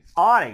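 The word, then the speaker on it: Aunegg: It's A-U-N-E-G-G. I know him. I've talked to him, but I don't know how to pronounce that. You want to give it a shot? – Onig. – Onig. That sounds good.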